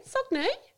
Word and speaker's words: Saignee: Yeah, it's spelled S-A-I-G-N-E-E. – Yeah, Sonnier. – I knew I was going to get tripped up, so I Googled it before I came in. Good on you. Never heard of that. Sonnier.